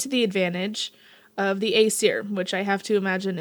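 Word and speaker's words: Æsir: The A-E is that fancy A-E that's like one letter. Mm. So it's Aesir.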